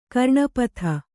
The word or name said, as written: ಕರ್ಣಪಥ